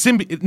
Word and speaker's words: symbiote: Isn't it symbiote? – Yeah, it's symbiote. No, it's a fucking symbiote. But they say it so goddamn weird in the trailers. They put the emphasis on the wrong syllable.